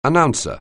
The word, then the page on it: announcer: anglais